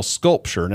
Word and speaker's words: SculpSure: And what I would say the best alternative to cool sculpting is called Sculpture. Now, it's not spelled like a work of art. It's spelled S-C-U-L-P-S-U-R-E.